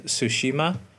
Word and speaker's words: Tsushima: Ik, vorige week was, was er een gameplay demo van 18 minuten van Ghost of Tsushima. Hè? Silent T trouwens, nu weten we uiteindelijk.